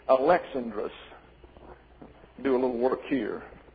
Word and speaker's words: Alexandros: That is spelled Alexandros. The accent is on the second syllable. Therefore, Alexandrus. Now, from this, we get the English name, Alexander. And we put the accent down here. But it's not Alexandros, it's Alexandrus, is the way it's pronounced in the Greek.